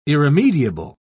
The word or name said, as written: irremediable